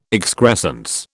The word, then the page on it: excrescence: British English